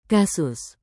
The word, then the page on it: kasus: Indonesian